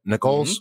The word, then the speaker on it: Nichols: Nichols? – Mm-hmm.